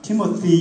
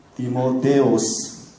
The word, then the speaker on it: 디모데: 영어로는 우리가 티모티 이렇게 하는데 그보다도 헬라어 발음이 디모데오스. 그러니까 우리가 더 훨씬 가깝습니다. 디모데가.